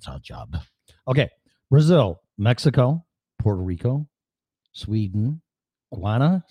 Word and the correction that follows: Ghana: Is that how you say it? Ghana, Guana, G-H-A-N-A. – Ghana.